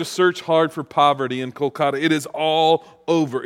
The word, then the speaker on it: Kolkata: That's a city that we used to call Calcutta, but it's pronounced Kolkata in the true translation, with a group of college students from the USA.